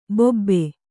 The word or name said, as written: ಬಾಬು